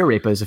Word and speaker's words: arepa: Uh, we actually made arepas a few weeks they're ago.